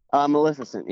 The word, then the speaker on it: Maleficent: Gaston, Cruella de Vil, Jafar, Scar, Maleficent, is that how you say it? – Maleficent, yeah.